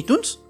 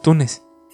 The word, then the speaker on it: Tunes: O sea, ahorita si ves una, un episodio de los Looney Tunes, dice Tunes. Sí. No, yo sí lo recuerdo con doble O, güey. – Por, porque así se escribe Tun. Ajá. Pero no, es Tunes. ¿Qué pedo, Jesucristo?